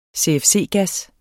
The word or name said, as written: CFC-gas